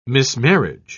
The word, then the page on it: mismarriage: British English